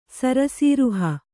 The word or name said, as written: ಸರಸಿರುಹ